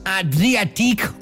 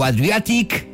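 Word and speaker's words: Adriatique: Es una colaboración entre los suizos Adriatic o Adriatic, pronúncialo como más te apetezca, junto con los Who Made Who.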